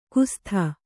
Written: ಕುಸ್ಥ

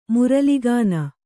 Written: ಮುರಲಿಗಾನ

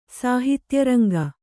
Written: ಸಾಹಿತ್ಯರಂಗ

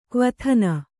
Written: ಕ್ವಥನ

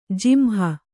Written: ಜಿಂಹ

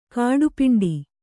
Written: ಗಟ್ಟಿಪಿಂಡ

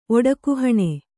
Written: ಒಡಕುಹಣೆ

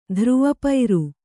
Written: ಧ್ರುವಪೈರು

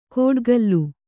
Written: ಕೋಡ್ಗಲ್ಲು

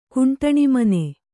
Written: ಕುಂಟಣಿಮನೆ

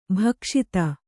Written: ಭಕ್ಷಿತ

